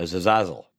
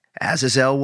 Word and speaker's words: Azazel: He's usually like a zazazzle. – Azazel. I think it's an Azazel.